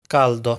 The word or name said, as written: Caldo